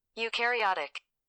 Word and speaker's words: eukaryotic: Do I lose points for saying it wrong? I'm going to say no, because I don't think I can say it either. Let's have the phone say it for us. – Eukaryotic. – Eukaryotic! That is how it's pronounced.